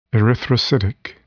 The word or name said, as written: erythrocytic